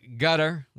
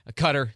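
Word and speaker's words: Qatar: But gutter, which is, I mean, I do not speak Arabic, but that's the kind of the close pronunciation that my Arabic speakers have told me to use. But cutter,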